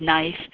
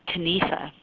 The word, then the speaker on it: knife: The word knife, K-N-I-F-E. Used to be pronounced something like Kanifa. The K was pronounced.